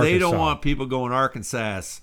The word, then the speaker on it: Arkansas: So, Arkansas!